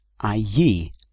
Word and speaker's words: allí: The Spanish for there is allí. Allí. That's spelled A-double-L-I, but pronounced allí.